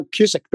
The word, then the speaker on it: Cusick: Is it Cusick or Cusick? – No, Cusick, the way you said it.